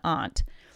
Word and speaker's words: aunt: Anyway, Grammarphobia says the word ant has two correct pronunciations, ant like the insect and aunt.